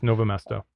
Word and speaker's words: Novo Mesto: How do we say it? – Just Mesto. No R. – Mesto? – Just Novo Mesto.